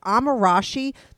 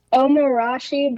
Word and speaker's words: Omorashi: And it's called, is Amarashi the correct way of pronouncing it? – I think it's Omarashi, but it's pronunciation's very, normally people call it just Omo as a shorthand, like O-M-O.